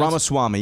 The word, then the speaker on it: Ramaswamy: Ramaswamy, yeah.